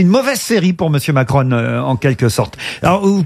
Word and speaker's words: Macron: Alors, je crois qu'on prononce Macron. – Macron, hein, ouais, parce que c'est une marque italienne, mais évidemment, quand on le lisait, on voyait Macron, Macron, et on disait, putain, c'est pas son époque à lui, hein, franchement.